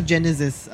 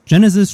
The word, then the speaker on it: GeneSEZ: Und dazu ist jetzt bei mir Genesis und ähm, vielleicht sagst du mal, man sagt zwar Genesis, aber irgendwie wird es ja ganz anders geschrieben. Vielleicht kannst du mal kurz sagen, warum das so heißt und wie man es dann überhaupt schreibt. Genesis schreibt man Genesetz.